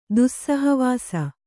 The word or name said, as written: ದುಸ್ಸಹವಾಸ